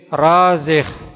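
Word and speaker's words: Razih (رازح): Bin Razih. Bin Razih. Ra-nya panjang. Zai kemudian ha.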